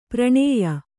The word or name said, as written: ಪರಿಣಯ